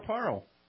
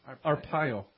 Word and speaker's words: Arpaio: Arpaio? – Brother Arparo. Arpaio.